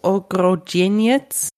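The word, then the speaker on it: Ogrodzieniec: Ich kann den Namen gar nicht so schön aussprechen wie du, Ogrodzin jetzt. Du rollst ja das R immer so schön, was ich gar nicht kann.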